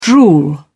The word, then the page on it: drool: British English